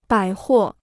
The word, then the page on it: 百货: bǎi huò